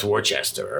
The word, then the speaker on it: Worcester: No, that's Worcester, all right? Like it, say it like Marky Mark.